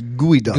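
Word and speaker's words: Geoduck: It says here that it's pronounced Gooey Duck.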